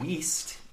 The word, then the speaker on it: Wiest: And Diane Wiest. Am I saying that right? Diane Wiest? – Yes. – Yes.